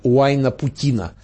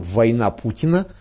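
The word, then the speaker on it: Уайнапутина: с названием Уайна-Путина. Правда, некоторые произносят это название более любопытным образом – «Война Путина».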